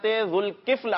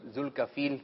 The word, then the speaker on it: ذوالکفل: تو سب سے پہلے جس نبی علیہ السلام کا تذکرہ کرنے جا رہے ہیں ان کا نام مبارک ہے حضرت ذوالکفل علیہ السلام. کیا نام ہے؟ ذوالکفل. لال واو. – ان کے نام کا تلفظ جو ہے وہ آتا نہیں ہے لوگوں کو صحیح نا, تو ذوالکفیل کہہ رہے ہوتے ہیں, تو اس کا تلفظ کیا ہے؟